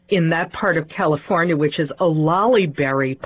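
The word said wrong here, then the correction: olallieberry: pie. – Olalleberry. – I think I'm saying it right. It's O-L-A-L-L-I-E.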